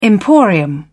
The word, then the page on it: emporium: British English